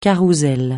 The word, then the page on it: carrousel: francais